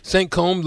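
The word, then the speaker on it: Saint-Cosme: Saint-Côme, like, is that like puffy combs? – Kind of, except it's spelled C-O-S-M-E.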